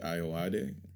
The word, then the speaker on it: Ayoade: I think that he reminds me a lot of Richard Ayoade. – Yeah. – Just, you did it. – I did. – You said it right.